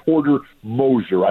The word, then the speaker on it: Porter Moser: This guy, Porter Moser, I hope I'm saying his name correctly.